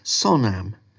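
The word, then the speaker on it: sonam (བསོད་ནམས): Now, the Tibetan word for merit is sonam, which in typical Tibetan spelling is spelt B, which you don't say, silent B, S-O-D, which you don't say, N-A-M-S. So, basod nams. But you actually say it sonam, which is much easier to say.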